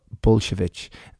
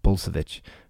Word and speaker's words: Bulsiewicz: We end here with Zach and move on a final note to the wonderful Dr. B, also known as Dr. Will Bulsiewicz. That's Polish pronunciation. I think in America it's called Bulsiewicz.